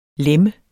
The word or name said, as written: læmme